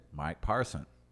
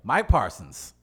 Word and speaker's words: Parson: Mike Parson. – Oh, you said it right. – That's right. I had no S. – Do it again. – Mike Parsons.